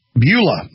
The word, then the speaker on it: Beulah: How do you pronounce that? To show my ignorance. Balua, is that right? Well, Beulah, North Dakota.